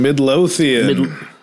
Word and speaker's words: Midlothian: Midlothian.